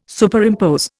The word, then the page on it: Superimpose: Indian